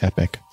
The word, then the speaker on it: epoch: Oh, it is pronounced epic. Listen.